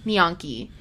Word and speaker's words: gnocchi: Found this little cafe, and I had the best gnocchi that I have ever.